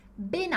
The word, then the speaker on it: ben: A gente tem esse ben, que aqui é uma redução do bene, né? Por quê? Porque altro começa com A.